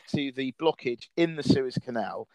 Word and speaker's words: Suez: You know, I remember you were mentioning Suez blockade. – Oh, that's right. – Apologies for my pronunciation. – No, you said it right there, because I kept on wanting to say sewage blockage, You said Swiss blockage.